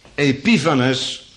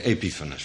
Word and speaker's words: Epiphanes: Epiphanes, moet ik zeggen, van mijn moeder. Ja, want als je dat leest, dan weet je nooit hoe je het moet uitspreken. Epiphanes, vooruit. Antiochus, Epiphanes.